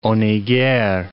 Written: Honegger

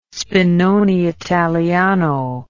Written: spinone italiano

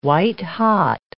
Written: white-hot

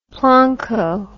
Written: plonko

